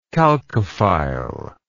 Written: chalcophile

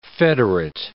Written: federate

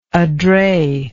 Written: adret

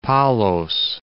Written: Palos

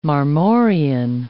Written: marmorean

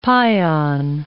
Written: pion

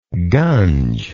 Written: gunge

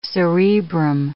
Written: cerebrum

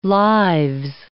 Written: lives

